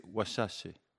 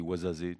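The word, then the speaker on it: Ouarzazate: Alors prononciation fidèle, c'est Ouachache. Mais en français, on dit Ouazazé, tout simplement.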